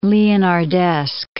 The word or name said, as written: Leonardesque